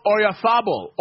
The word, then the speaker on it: Oyarzabal: Femi Abefe, Joe Cirillo, as Mikel Oyarzabal, or as some pronounce it, say Oyarzabal.